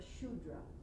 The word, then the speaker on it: Shudra: The lowest stage of the first caste, so to speak, caste in terms of consciousness, a Shudra is how you pronounce that word.